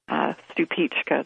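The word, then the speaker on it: Stupice: So that variety that I'm speaking about is a little Czechoslovakian heirloom called Stupicka. That's the pronunciation, but the spelling is S-T-U-P-I-C-E. I love that one.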